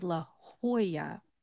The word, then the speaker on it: La Jolla: The two L's are going to become a Y. So it becomes La Jolla, Jolla, when you pronounce it.